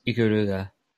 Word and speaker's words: Ikaruga: Ikagura, I'm probably butchering that, but it's... – Ikaruga.